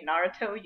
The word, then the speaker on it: Naruto: I coached you on how to say Naruto. You just pronounce it like they say Naruto, not Naruto.